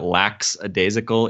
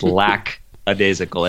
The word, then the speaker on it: lackadaisical: And just for everybody out there, if you've ever been misled into thinking that lackadaisical is the way to pronounce that word, it's not. It's lackadaisical. It has nothing to do with lax and relax or any of those things.